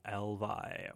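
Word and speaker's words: EL VY: That, you know, they've called themselves Elvy, I think it's pronounced. It's Elvy. According to a press release, it rhymes with hell pie.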